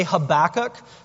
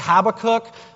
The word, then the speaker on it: Habakkuk: And quite frankly, scholars can't agree on how to pronounce it. And so I'm going to say Habakkuk. Some people say Habakkuk. The traditional Hebrew way of saying it is Habakkuk.